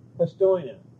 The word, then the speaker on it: Postojna: How do you pronounce it? Prosinoy Cavern? – Postojna.